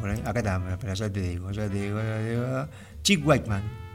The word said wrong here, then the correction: Wickham: Wickham, así se llama este muchacho, que lo hemos escuchado en otras intervenciones semanas atrás.